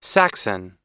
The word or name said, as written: saxon